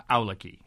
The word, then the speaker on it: Awlaki: This individual, Ikhwan, the Arabic word for brother, Awlaki, is how he's pronouncing it.